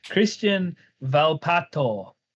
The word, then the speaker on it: Cristian Volpato: Cristian Volpato.